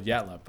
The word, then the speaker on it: Yatala: The one thing that annoys me, though, is Yatla should be pronounced Yatala because it's spelled Yatala, Y-A-T-A-L-A, but it's called Yatla.